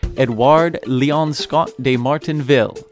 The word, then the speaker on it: Édouard Léon Scott de Martinville: So, for your reference, our American pronunciation is Édouard Léon Scott de Martinville.